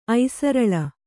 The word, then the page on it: ಐಸರಲಿ: kannada